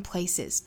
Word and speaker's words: places: 元音连读加 Many are stronger at the broken places. – 同样 at 和 the 两个虚词做弱读，读得很短快。那么 at 后面这个 t 的发音呢，也是略掉了。 At the broken places. 注意最后的 place 变复数，读浊辅音的 is, says, places, places.